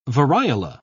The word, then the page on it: variola: British English